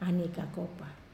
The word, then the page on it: aniai kakopa: Wamesa